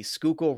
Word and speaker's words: Schuylkill: So one of the major roads in Philadelphia runs along the Schuylkill River.